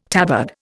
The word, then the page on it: tabard: Indian